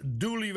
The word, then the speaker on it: Dula: En ze werd vermoord door Tom Dula, wiens naam in het lokale dialect Dooley werd uitgesproken.